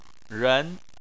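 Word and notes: ren: tone 2

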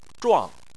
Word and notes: zhuang: 4

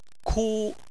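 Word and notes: ku: tone 1

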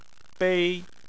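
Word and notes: bei: tone 1

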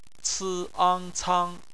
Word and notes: cang: bpmf

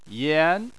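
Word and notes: yan: tone 2

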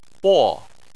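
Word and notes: bo: tone 4

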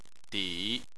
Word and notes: di: 3

